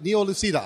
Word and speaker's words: Neo Lucida: Neo Lucida, I'll say it in the right, the Italian pronunciation.